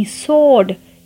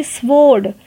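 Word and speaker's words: sword: S W O R D सोड सोड ना कि स्वोड तो सोड यानी तलवार सो so, यहाँ पे भी डब्ल्यू साइलेंट है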